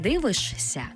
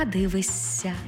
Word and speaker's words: дивишся: Наприклад, не «дивишся», а «дивися».